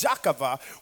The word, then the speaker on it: Jochebed: And really probably better pronounced Jacava,